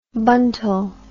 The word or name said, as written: buntal